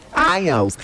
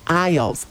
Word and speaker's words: aisles: Hay aisles. Él se dice aisles. Aisles.